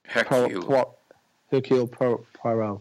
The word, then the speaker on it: Hercule Poirot: Is it Hercule Poirot? – Yeah.